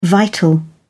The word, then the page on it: vital: British English